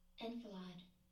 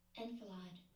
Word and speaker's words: enfilade: Enfilade. That's how you say it, guys. Enfilade.